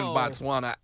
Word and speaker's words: Botswana: Botswana? Am I right? – Botswana, Africa. That's right.